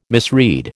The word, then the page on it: misread: American English